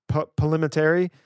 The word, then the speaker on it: Palminteri: Chaz Palminteri.